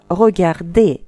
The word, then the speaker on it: regarder: Вот, например, глагол первой группы «regarder» в инфинитиве «r» на конце не произносится, Но когда мы от него образуем conditionnel présent, R будет произноситься.